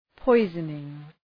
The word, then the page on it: poisoning: ‘pɔızənıŋ